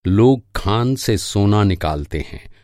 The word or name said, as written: निकालना